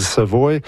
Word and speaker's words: Savoy: Je ne sais pas s'ils les prononcent Savoy, mais en Louisiane, on dit Savoy.